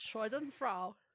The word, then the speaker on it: Schadenfreude: But Schadenfreude.